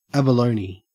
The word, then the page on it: abalone: Australian English